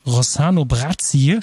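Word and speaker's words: Rossano Brazzi: Und ich muss sagen, mich hat der Rosano Brazzi, ich hoffe, ich habe das richtig und oder wahrscheinlich falsch ausgesprochen. – Ah, das passt schon.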